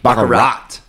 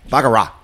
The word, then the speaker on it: baccarat: Is it a hard T at the end? – It's a hard T. – Baccarat. – Yeah. – Baccarat. – I don't hear the T. Baccarat. – You could say it like that. – I'm going to stick with baccarat.